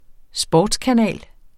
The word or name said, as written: sportskanal